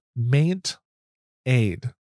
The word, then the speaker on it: MaintAid: The next, another app is called Maint Aid. So Maint, like maintenance, and Aid. So it's spelled M-A-I-N-T-A-I-D.